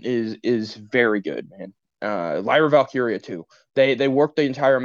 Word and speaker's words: Valkyria: Lyra Valkyria, my guy. – Valkyria, is that what it is? I thought it was Valkyrie. – I wrote it down as Valkyrie. – Well, that would be why you mispronounced it.